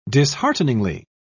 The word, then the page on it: dishearteningly: British English